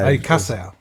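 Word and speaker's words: Alcácer: Alcacer.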